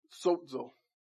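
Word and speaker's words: Sozo (σῴζω): Sozo. The easiest way to do it is S-O-Z-O.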